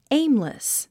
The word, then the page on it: aimless: éimlis; エイムレス